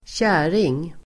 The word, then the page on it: kärring: [²tj'är:ing]